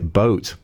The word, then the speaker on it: Both: It's actually B-O-T-H, but I suppose you pronounce it boat.